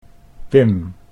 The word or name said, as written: Pim